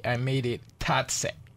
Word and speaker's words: Tatse: Tatse Restaurant.